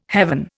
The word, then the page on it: heaven: Indian